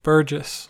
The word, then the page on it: burgess: American English